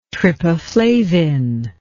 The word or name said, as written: trypaflavine